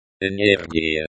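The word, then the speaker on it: энергия: Note the stress on the second to last syllable of the word. Energia.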